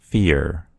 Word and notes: fear: American English

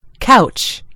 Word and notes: couch: American English

